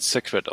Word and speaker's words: Sacred: Du meinst, das du meinst äh, ich meine aber glaube, das wird Sacred ausgesprochen.